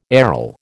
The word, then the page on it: aril: American English